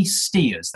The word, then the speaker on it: Stears: So I would go, oh, yes, yeah, sorry, it's Anthony Stears.